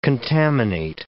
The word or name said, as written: contaminate